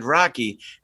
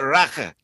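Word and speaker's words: rakı: I pronounced it wrong when I got there. I said Rocky, and they have it's like a short A sound, which is strange because it's spelled R-A-K-I. They call it Raka, like that.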